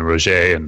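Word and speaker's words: Roggae: And is it like Roger, like R O W J, like kind of like? – No. I just think of it as like, you know, soft G, like Mike Soft G Jordan.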